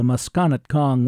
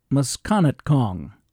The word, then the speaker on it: Musconetcong: And I know those pronunciations are correct because I called the Musconetcong Watershed Association to make sure. That's right, Musconetcong.